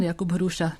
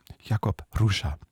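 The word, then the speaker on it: Jakub Hruša: Wie wird der nochmal ausgesprochen? – Pan Jakub Hruscha, dobrý den. – Dobrý den. – Dobrý den. Jakub Hruscha heißt er, der Dirigent.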